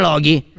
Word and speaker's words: Loki: No, non cambia, la pronuncia non cambia, è sempre Loki.